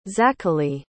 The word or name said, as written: zachely